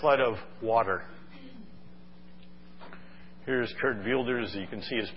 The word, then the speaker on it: Wilders: It's Geert Wilders. It looks like in English, W-I-L-D-E-R-S, Wilders, but it's pronounced Wilders.